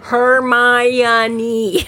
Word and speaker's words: Hermione: and then near the end of the second book, it actually had somebody phonetically pronounce it Hermione.